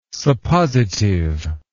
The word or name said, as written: suppositive